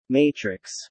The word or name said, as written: matrix